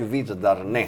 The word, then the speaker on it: Virdarne: Och du, du, är alltid så bra på att uttala det. Jag har hört Värend, dig göra en gång till. Nu tar Värend, och, Värend och Virdarne. Virdarne.